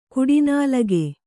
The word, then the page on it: ಕಡುನಾಲಿಗೆ: kaḍunālige